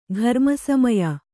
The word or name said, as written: ಘರ್ಮಸಮಯ